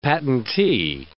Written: patentee

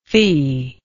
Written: feeless

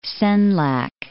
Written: Senlac

